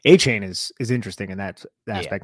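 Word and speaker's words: Achane: Or, yeah, A-Kane. We're talking about guys and the Giants. What? – Still wrong. – A-Chain, what is it? – A-Chain. – A-Chain, A-Kane, A-Chain, whatever.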